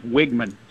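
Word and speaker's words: Weigman: The E throws things off, because it's spelled W-E-I. – Yeah. – It is Wigman, W-I-G. Just think of there you go.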